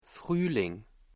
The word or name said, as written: Frühling